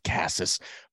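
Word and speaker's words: Casas: One thing I love about Cassis, and I wish it was just Cassis. I wish we could all just agree it's pronounced Cassis.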